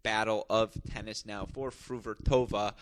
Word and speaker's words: Fruhvirtova: I'm talking about Linda Fruvertova, a name we're all going to have to learn to pronounce as Fruvertova,